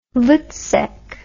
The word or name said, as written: voetsek